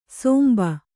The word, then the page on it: ಸೋಂಬ: kannada; sōmba